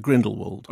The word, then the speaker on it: Grindelwald: No, actually, I think I'm a W rather than a V. – But you just said Grindelwald. – I know. There's a lack of consistency in my pronunciation. – Yes, you should make a decision and stick with it. – I'm going to go with Grindelwald.